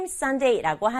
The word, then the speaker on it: sundae: sundae는 아이스크림 sundae라고 하는데요. 보통은 아이스크림 위에다가 시럽이나 뭐 과일이나 이런 토핑 올려 먹는 아이스크림을 sundae라고 해요. 발음은 일요일 할 때 sundae랑 똑같은데 쓸 때는 이렇게 쓴다는 것도 친구들 잘 알아둬요.